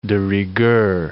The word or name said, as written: de rigueur